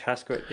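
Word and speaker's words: Hascoët: Is that how you'd pronounce that? Hasquit? – Serge Hasquit, yeah.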